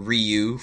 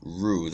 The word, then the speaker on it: Ryu: It's not Ryu for all of you Street Fighter fanatics. It's Hyunjin Ryu. That's the correct pronunciation.